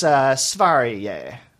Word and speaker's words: Sverige: So the name of Sweden in Swedish, as we would spell it, S-V-E-R-I-G-E, is closest. The best I can do is Sverige. It's a soft. There's no G sound, yeah.